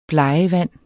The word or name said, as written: BLEGVAND